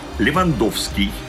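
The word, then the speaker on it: Lewandowski: Именно так звучат и в русском языке тоже польские фамилии. Так что, пожалуйста, Щенсный, Ковалевский, Шиманский, Левандовский. Нет, Уэйн Грецки, здесь никакого экранного не надо, он канадец. Так что будем произносить правильно, тем более, что я уверен, фамилия Левандовского скоро начнет звучать еще чаще и звонче, но если это вообще возможно.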